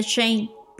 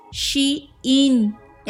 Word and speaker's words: SHEIN: Y hablando de Shane, que spoiler alert, no se dice Shane, porque es She-In.